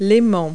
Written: magnet

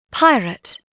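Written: pirate

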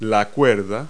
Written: string

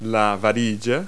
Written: stick

